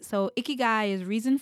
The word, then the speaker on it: ikigai: And it's the Japanese concept of ikigai. Right? I have such, like, it's spelled I-K-I-G-A-I. It took me forever.